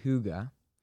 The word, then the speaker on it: hygge: écrit H-Y-G-G-E, prononcé Huga.